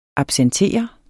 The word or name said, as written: absentere